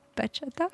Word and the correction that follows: bachata: C'est pas comme ça qu'on dit. – Comment on dit alors? – Vous êtes des prouveurs. – L'accent tonique est sur le deuxième A, je pense. – Bachata. – Ouais. Eh oui, bravo.